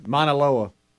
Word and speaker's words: Mauna Loa: M-A-U-N-A-L-O-A. – Some weird Hawaiian word. – Mauna Loa.